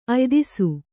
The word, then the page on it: ಅಯ್ದಿಸು: Kannada